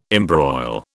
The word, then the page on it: embroil: British English